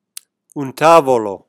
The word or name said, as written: tavolo